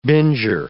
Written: binger

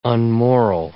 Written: unmoral

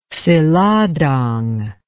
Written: seladang

